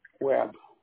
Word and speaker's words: Webb: Webb. W-E-B-B.